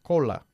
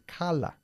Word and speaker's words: color: No se dice cola, es cala.